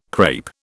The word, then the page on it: crape: British English